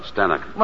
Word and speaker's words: Stanwyck: What's that, Stanwyck? – Well, that's how they said Stanwyck in England. Oh. Zanuck, it rhymes with Zanuck.